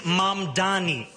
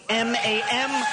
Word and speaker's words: Mamdani: And furthermore, the name is Mamdani. Mamdani. You should learn how to say it because we got to get it right.